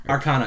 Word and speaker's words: Arcana: Arcana? – Arcana is how I heard it pronounced.